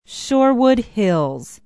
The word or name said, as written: shorewood hills